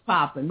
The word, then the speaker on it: Papen: I checked a German speaker on the pronunciation of Papen, and yeah. – It is Papen, which sounds funny to me. I kind of like the Papen, but it's like the ah sound in Maul, this person said, but I can think of it like Hans, Hans Lammers, Papen.